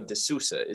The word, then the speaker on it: D'Souza: Don't pronounce his name as if he's like a new nickname for Chief Keef. Okay, it's Silvio D'Souza.